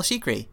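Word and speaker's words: Chriqui: Jim Gaffigan comes back because he has a bit part in the other one, but also Emmanuel Shikri. I don't know how to pronounce her name. Sloan from Emmanuel Shikri.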